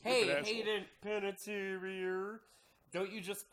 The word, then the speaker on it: Panettiere: Oh, no, it's Hayden Panettiere.